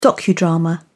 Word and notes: docudrama: British English

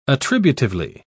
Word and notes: attributively: British English

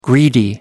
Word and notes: greedy: American English

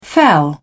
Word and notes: fell: British English

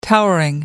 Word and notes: towering: American English

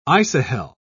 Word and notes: isohel: British English

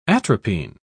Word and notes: atropine: British English

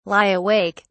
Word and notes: lie awake: British English

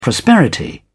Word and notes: prosperity: British English

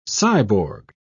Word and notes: cyborg: British English

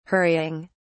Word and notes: hurrying: British English

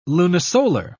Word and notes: lunisolar: British English

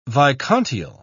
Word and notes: vicontiel: British English

